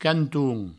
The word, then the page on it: canton: provençal; cantoun